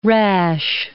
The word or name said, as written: rash